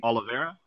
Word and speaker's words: Oliveira: And so I would have liked to seen him against like an Olivieri, but or Oliver. How do you say his name? – Oliveri? – Oliveira, I think. – Oliveira? – Yeah. Oliveira, I think he's number.